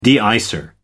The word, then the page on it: de icer: American English